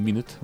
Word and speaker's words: Minit: Pisze się Minit, a czyta się niby Minut, bo chodzi o to, że mamy 60 sekund na rozgrywkę.